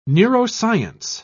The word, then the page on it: neuroscience: British English